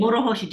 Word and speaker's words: Morohoshi: Yes, Morohoshi. – Morohoshi, just roll the R a little bit.